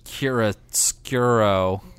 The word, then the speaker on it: chiaroscuro: Yeah, well, I mean, Martha had its, like, very, you know, naturalistic lighting theme, where this is all about chiaroscuro. – Close. – Say it for me. – Well, now I'm in my head. Chiaroscuro. – Chiaroscuro? – Yeah, I'm pretty sure that's how you say it.